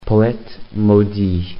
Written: poete maudit

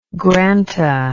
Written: granta

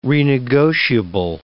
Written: renegotiable

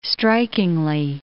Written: strikingly

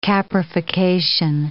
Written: caprification

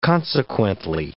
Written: consequently

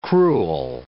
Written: cruel

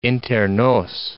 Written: inter nos

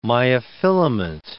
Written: myofilament

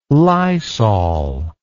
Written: lysol

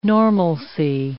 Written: normalcy

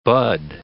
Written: bud

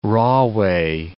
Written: Rahway

